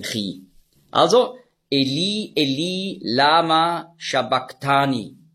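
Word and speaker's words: sabachthani: Und das CH im Griechischen, das ist der ein Buchstabe, das Chi, das hat man ausgesprochen so wie ein C hinten im Gaumen. K. Also nicht Shabach, sondern Shabak.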